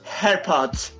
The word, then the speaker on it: AirPods: Sería AirPods. Es que la pronunciación es AirPods.